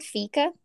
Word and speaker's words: fika: Fika? – Yeah, it's spelled F-I-K-A.